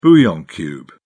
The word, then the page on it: bouillon cube: British English